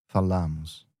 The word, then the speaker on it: falamos: And then the stress in the verb changes a little bit. It's not the first syllable, as in falo, but the middle one, falamos.